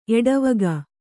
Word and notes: ಎಡೆವುಗು: kannada

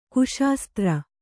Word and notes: ಕುಶಾಸ್ತ್ರ: kuśastra

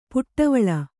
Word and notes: ಪುಟ್ಟವಳ: puṭṭavaḷe

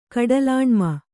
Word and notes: ಕಡಲಾಣ್ಮ: Kannada

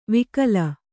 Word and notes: ವಿಕಲ: vikala

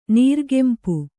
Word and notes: ನೀರ್ಗೆಂಪು: kannada

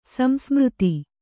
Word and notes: ಸಂಸ್ಮೃತಿ: kannada

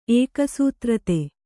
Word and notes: ಏಕಸೂತ್ರತೆ: ēkasūtrate

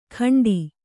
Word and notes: ಖಂಡಿ: kannada

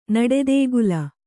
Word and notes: ನಡೆದೇಗುಲ: Kannada

